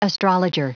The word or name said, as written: astrologer